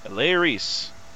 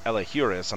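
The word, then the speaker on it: Elehuris: What I would like to know is how do you pronounce that first name? – Elairis. – Elairis. – Elairis. It's really interesting. Yeah. So I thought it was I thought it was Elahuris, and I was reading it very like, um, you know, much how, how it was spelled, but it's Eleuris.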